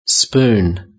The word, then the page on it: spoon: British English